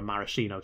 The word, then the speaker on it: maraschino: Shirley Temple is a non-alcoholic cocktail made of ginger ale and grenadine with a mascherino cherry. Or a maraschino cherry. I always say that wrong.